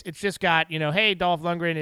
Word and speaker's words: Dolph Lundgren: but it highlights Dolph Lundgren. Is that how you say his name? Dolph Lundgren? – Yeah.